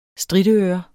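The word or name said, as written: STRITØRER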